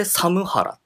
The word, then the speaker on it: サムハラ: これでサムハラって読むんですけど。